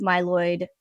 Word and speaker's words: myeloid: had died from acute myeloid leukemia. Am I saying that right, John? – Yes, yes.